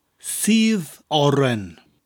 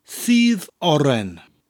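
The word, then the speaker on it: sudd oren: Spelt S-U-D-D, that's the word for juice, and oren, as in orange, is spelt O-R-E-N. The first word, seethe, sounds like the English word, or you seethe in anger. So it sounds like that word, seethe, followed by oren.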